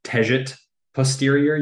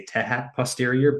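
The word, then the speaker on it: Tejat Posterior: So like you could say Tejet posterior, you could say Tehat posterior, but usually I call it Tejet posterior, is this kind of like wounded ankle star.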